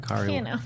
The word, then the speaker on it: Kari: Yeah, I got to meet Kari Walgren, which she spells her name like me, but she pronounces it Kari, who she's...